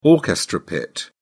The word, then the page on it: orchestra pit: British English